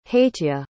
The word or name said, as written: hateya